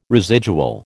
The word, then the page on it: residual: American English